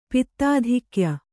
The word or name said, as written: ಪಿತ್ತಾಧಿಕ್ಯ